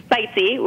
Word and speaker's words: Bitesee: Bitesee, which, you know, rhymes with sightseeing, because that's, I consider myself a culinary traveler.